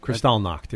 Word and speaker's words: Kristallnacht: Kristallnacht, yeah.